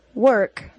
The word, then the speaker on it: work: Work, though it is spelled with an O-R, is pronounced as schwa plus R.